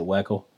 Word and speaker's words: Weckel: So like even my last name, I pronounce it Weckel.